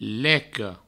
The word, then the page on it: leca: provençal; leco